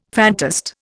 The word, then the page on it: fantast: Indian